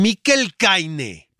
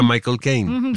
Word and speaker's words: Michael Caine: Estaba yo en San Sebastián y llega publicista y me dice que eh, tenés una entrevista con Miquel Caine pero te lo dicen como si así se pronunciara, y era Michael Caine.